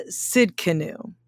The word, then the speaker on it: Tsidkenu: Jehovah Tsidkenu. That's right, Jehovah Tsidkenu. You may not know this one very well. It's spelled T-S-I-D-K-E-N-U, Tsidkenu, which means the Lord our righteousness, the Lord our righteousness.